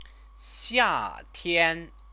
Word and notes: xiatian: chinese

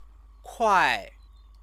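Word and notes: 快: kuài

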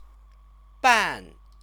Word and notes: 半: bàn; Chinese

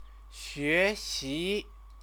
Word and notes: xuexi: Chinese

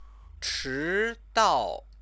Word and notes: chidao: chinese

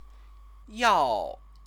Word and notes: yao: chinese

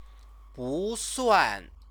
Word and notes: busuan: chinese

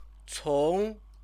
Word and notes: cong: Chinese

